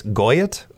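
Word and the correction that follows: Gotye: or Goit. 44 today. Had a hit some years ago. Somebody that I used to know. – Godier. – Thank you. I knew it was mm-hmm. Godier.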